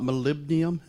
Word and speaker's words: molybdenum: We talked a little bit about molybdenum, if I'm saying that right. – Yep.